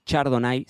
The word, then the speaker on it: Chardonnay: Chardonnay, Chardonnay se escribe, pero lo pronunciamos Chardonnay Chardonnay es la reina indiscutida de las variedades de uvas blancas, sobre todo porque está en todos rincones del mundo.